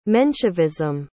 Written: menshevism

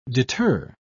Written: deter

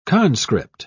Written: conscript

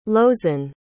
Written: lozen

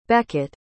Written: beckett

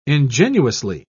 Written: ingenuously

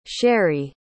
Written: Sherree

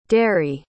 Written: dary

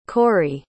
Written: cory

